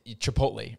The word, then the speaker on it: Chipotle: Chipotle? – Chipotle, right? – Chipotle. – Is that how you say it? – No, you're saying it right.